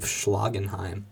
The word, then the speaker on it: Schlagenheim: Of course, I'm talking about Black Midi's debut album, the Oxford, England band, noise band, their debut album of Schlagenheim. – Is that how you say it? – Yeah.